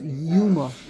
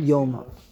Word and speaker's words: yuma: that the correct pronunciation is yuma, not yoma. Okay, like they say in yeshiva, yuma.